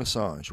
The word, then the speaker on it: Assange: Julian Assange, right.